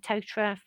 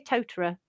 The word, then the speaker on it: Totara: Well, one thing I've learned from this news section, is it's pronounced Totara and not Totara.